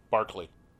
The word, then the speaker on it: Berkeley: Q-U-A-Y. – Q-U-A-Y. – How do you pronounce that word? – Key. – B-E-R-K-E-L-E-Y. – B-E-R-K-E-L-E-Y? I would pronounce that as Berkeley. – Barkley. – That's wrong. – No, it's not. – Barkley? – D-E-R-B-Y-S-H-I-R-E.